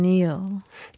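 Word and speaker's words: kneel: and Neil. – K. – The K, which was silent until I spelled it earlier in the courtroom.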